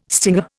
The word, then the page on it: stinger: Indian